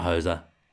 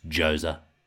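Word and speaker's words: José: Jose Mourinho, by the way, Greg, did you not watch the Spurs documentary on Amazon when he was adamant about how you say his name? Not Jose, it's Jose.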